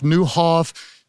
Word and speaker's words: Nieuwhof: N-I-E-U-W-H-O-F, pronounced Niehoff, Newhoff, Niehoff, all sorts of things. Newhoff, I think, is the right way.